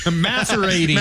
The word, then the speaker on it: macerating: I'm masturbating. – Macerating. – Macerating. Oh, sorry.